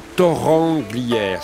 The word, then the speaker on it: Torrens-Glières: Et pour notre part, nous y serons le samedi soir, repère des repères de la basse, si j'y suis, à Torrent-Glières, ça s'écrit Torrens-Glières, et c'est à une petite demi-heure d'Annecy.